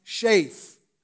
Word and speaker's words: Sheth: Seth is translated from the Hebrew word Sheth, S-H-E-T-H, pronounced Shaith.